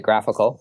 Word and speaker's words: GraphQL: We pronounce it GraphQL.